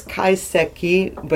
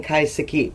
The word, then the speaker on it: Kaiseki: Well, the Americans have a tendency to pronounce every single syllable. – The Americans. – The Americans, I'm sorry. So it is Kaiseki, but sometimes it's like I'm used to just sort of pronouncing it in a different way. So I am saying Kaiseki, but it's just closer. – Quicker. Actually. It's actually quicker.